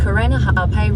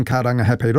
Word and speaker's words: Karangahape: That was TVNZ's Māori Issues reporter Te Aniwa Huri Hanganui driving around Auckland and unimpressed with the navigation voice on the Google Maps app mispronouncing Karangahape Road and other places with Māori names.